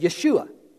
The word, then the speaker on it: Yeshua: The, the Jews would have said, uh, they would have said Yeshua. When they wrote the New Testament in Greek, it was Jesus. In English, as a hard J, it would be Joshua, Yeshua, Joshua.